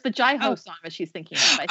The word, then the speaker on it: Jai Ho: Pronounced Jai Ho.